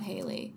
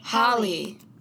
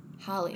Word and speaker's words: Halle: Holly. – Oh. Holly. Like Halle Berry. Halle Bailey.